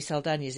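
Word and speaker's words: Saldaña: I'm not going to go Zoe Saldana's in it, because that's technically how you pronounce it, because it's got one of them tildes over the N. – Ooh. – Because she's Latin American. – Ah. – So it's Saldana.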